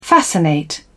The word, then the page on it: fascinate: British English